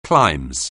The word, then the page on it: climes: anglais